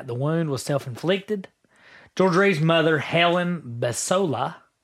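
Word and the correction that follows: Bessolo: Besolo. – What is it? – Helen Besolo. – Besolo, I'm sorry.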